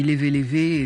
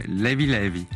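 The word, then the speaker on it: Léve Léve: DJ Tombé est notre invité pour la sortie du volume 2 de ses compilations sur Sao Tomé et Principe, qui s'appelle, moi je dis Levé Levé, et ça ne se prononce pas comme ça. Comment ils disent là-bas ? – Levé Levé.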